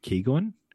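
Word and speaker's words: Keoghan: I believe you pronounce his last name, Keegan.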